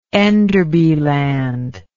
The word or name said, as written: Enderby Land